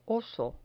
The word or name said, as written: oso